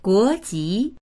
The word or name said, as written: guo2ji2